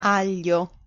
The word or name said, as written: aglio